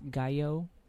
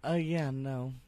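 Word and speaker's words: Gallo: I mean, yeah, if you want to follow me on Twitter, it's Kaydence Gayo. – Spell it, please. – So it's KAYDENCEGALLO. – All right. – At Kaydence Gayo.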